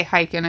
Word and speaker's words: Hiken: I'm going to say Hiken is his name. That's how I'm pronouncing it. – Yeah. – It's H-I-K-E-N. Like it, correct. It looks like that's what it would be. Yes.